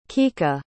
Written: kikka

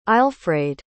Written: aelfraed

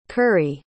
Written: currie

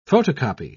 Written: photocopy

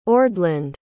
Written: ordland

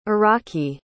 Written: iraqi

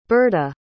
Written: Berta

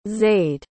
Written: zaide

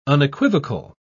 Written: unequivocal

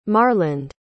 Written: marland

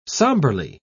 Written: somberly

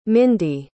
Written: mindy

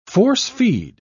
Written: force-feed

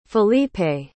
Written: filipe

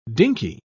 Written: dinkey

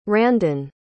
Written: Randon

